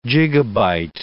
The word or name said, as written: gigabyte